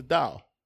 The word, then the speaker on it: Tao: It's T-A-O, but it's pronounced Tao.